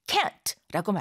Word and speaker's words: can't: 발음할 때 모음을 약간 길게 해서 강하게 can't, can't라고 하고요. 실제로 할 수 있다라고 할 적엔 근, 근 이렇게 약하게 거의 뭐 큰 정도로밖에 발음이 안 될 경우도 있어요.